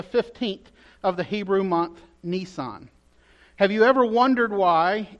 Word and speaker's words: Nisan: So the first month of the Hebrew calendar is called Nisan, spelled like a Nisan with one less S, N-I-S-A-N.